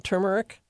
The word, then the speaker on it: turmeric: The accent is on the first syllable of the word turmeric. At least according to the online dictionary of pronunciation, I had to relearn the pronunciation also. I had never noticed the R.